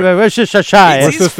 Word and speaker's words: Worcestershire: De Worcestershire. – Worcestershire. Ouais, Worcestershire.